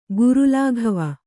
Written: ಗುರುಲಾಘವ

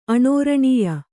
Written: ಆನೃಣ್ಯ

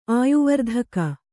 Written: ಆಯುವರ್ಧಕ

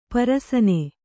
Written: ಪರಸನೆ

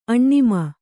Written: ಆಣ್ಮ

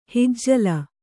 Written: ಹಿಜ್ಜಲ

